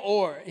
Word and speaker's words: Oher: Michael Orr. I think that's how you pronounce it. O-H-E-R. Thank you. Michael Orr.